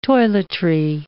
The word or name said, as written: toiletry